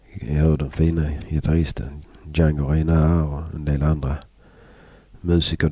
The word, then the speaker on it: Django Reinhardt: Det här är från en skiva som heter Django Reinhardt, eller Django Reinhardt som det egentligen ska uttalas, And the Singers with Jean Sablon.